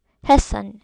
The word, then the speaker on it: Hessen: I said I would say it right. Hessen, okay.